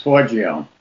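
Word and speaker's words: Forgione: And I've got 6 or 7 here that I know of, with Joe Gilliatt, Andre LeBlanc, Don Heck, George Olsen, Bob Forgoyne, Rick Buckler, and Carmen Infantio. – The name is Forgio.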